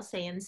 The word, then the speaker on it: Seien: I asked her about her brand name, and it's spelled S-E-I-E-N style, Saiyan style.